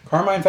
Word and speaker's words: Carmine: I was like, is it Carmine? Yeah. Carmine Falcone, big-ass mob boss in Gotham. I think this is an excellent choice. – Yeah. I didn't love the casting of Carmine Falcone in Batman Begins.